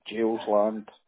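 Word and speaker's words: Geilsland: Is it G-E-I-L? – Yep. G for golf. E-I-L-S-L-A-N-D. – S-L-A-N-D. – A for Alpha, N-D, yeah. Jail's Land.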